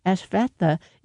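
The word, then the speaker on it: Ashvattha: Ashvatha is spelled Ash, A-S-H, Vat, V-A-T-T, Ha. That's Ashvatha Therapeutics.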